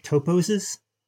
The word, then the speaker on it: toposes: So in the final chapter, chapter 7, you focus on, and you'll have to correct my pronunciation, toposes? – Yeah.